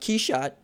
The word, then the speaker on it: Quichotte: how long did it take us to learn that Quichotte was the French pronunciation of Quixote? – I believe French, yeah.